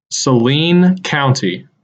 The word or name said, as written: Saline County